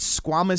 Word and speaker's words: squamous: I believe it's called squamous cell cancer.